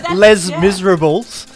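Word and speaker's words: Les Misérables: Of course you have to say it in French. – Well, that's how it's pronounced! Les Miserables.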